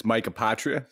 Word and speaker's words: Apotria: That was probably better than, like, I would say 80% of people do on their first shot. So yeah, it's Mike Apotria.